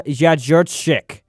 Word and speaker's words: Jędrzejczyk: Joanna Jędrzejczyk. I pronounced that correctly. That's extremely hard to pronounce it. It's J-E-D-R-Z-E-J-Z-C-Z-Y-K.